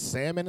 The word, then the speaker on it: salmon: But salmon is fucking you up, bitch. Get the fuck out of here. The L is silent.